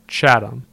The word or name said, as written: Chatham